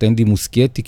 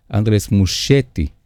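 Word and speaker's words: Andrés Muschietti: לקח לי הרבה זמן לברר איך מבטאים את השם שלו, הוא ארגנטינאי, אז אני חושב שנטייתנו בארץ היא לקרוא לו קצת אנדי מושייטי, כי אנחנו חושבים שהוא איטלקי, אבל לא, הוא ארגנטינאי, אנדלס. אנדרס מושייטי, מושייטי,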